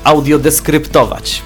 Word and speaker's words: audiodeskryptować: Ja przypominam, że dziś rozmawiamy na temat audiodeskrypcji, różnych, bo różne wydarzenia i różne rzeczy można audiodeskryptować, bo się dowiedziałem, że tak się powinno to wymawiać.